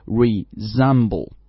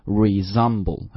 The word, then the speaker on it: resemble: 啊，读的时候注意 resemble. 这个地方的那个 s 的发音不读 s，应该读 z 啊，要把它浊化。所以再来一遍 resemble.